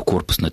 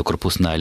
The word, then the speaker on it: корпусная: Дело в том, что с конца 90-х годов в науке возникает новое направление, которое, кстати, называется то корпусная, то корпусная лингвистика. До сих пор ученые не пришли к единой точке зрения, куда что-то ставить ударение.